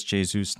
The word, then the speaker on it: Jesus: Thanks, Thelma, for pointing out it is Jesus, not Jesus.